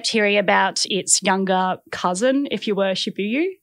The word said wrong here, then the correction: Shiba Inu: Is that how you say it? – Shiba Inu. – Yeah, that's it.